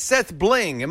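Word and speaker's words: SethBling: I think it's Seth Bling.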